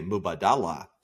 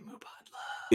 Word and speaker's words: Mubadala: Until you like hear somebody real say it, everybody wants to say Mubadala. – Mubadala. Yeah, exactly. I feel like such an insider being like, it's actually Mubadla.